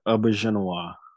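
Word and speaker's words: Auberjonois: Great Rene Auberginois.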